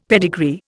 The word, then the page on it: pedigree: Indian